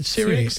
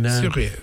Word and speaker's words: Cyriax: Is that how you pronounce it? I'm often wondered. Cyriax. So you pronounce the X normally in... Cyriax. Normally in Syria, isn't it? – Syria.